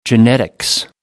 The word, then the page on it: genetics: American English